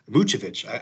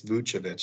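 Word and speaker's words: Vučević: I had some New Year's resolutions for the Bulls, and I had some specifically for Nikola Vucevic and for Vucevic. I've been pronouncing it wrong. I apologize to all the listeners out there. I just looked up today. It's Vucevic.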